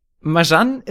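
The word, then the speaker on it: Marjane: Ich glaube, sie heißt nämlich Marjan. Du hast diesen diesen das das J viel zu deutsch ausgesprochen, Jan.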